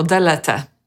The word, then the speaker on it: delete: Delete. Akcent jest na drugą sylabę, czyli na lead.